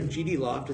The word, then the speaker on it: GDLOFT: you guys are GD Loft.